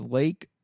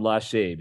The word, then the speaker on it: Lachey: His name is Lake C. Strunk. – Lachey. – It's spelled Lachey, but his name's Lake. – I call him Lachey because that's ridiculous to think that means lake.